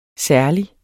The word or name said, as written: særlig